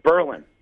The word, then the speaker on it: Berlin: The number one thing that I need to tell people about Berlin is don't call it Berlin. They get mad. It's right. – Berlin. All right? One syllable.